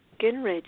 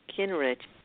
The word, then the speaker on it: Guengerich: Guengerich. Well, I would have never thought that. Guengerich.